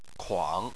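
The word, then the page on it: kuang: tone 3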